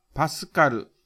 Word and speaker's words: Pa: 括弧 5 の PA はパスカルと読み、圧力の単位です。